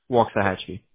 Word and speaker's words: Waxahachie: So you got companies like Mr. Electric of, I'm so sorry, how do you pronounce that? Waxahachie? – Yes, Waxahachie.